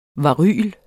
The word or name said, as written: varyler